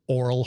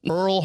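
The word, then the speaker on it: Orel: I could not pronounce his name correctly at all. Earl Hershiser. And I'm like, oh my God, what are you doing?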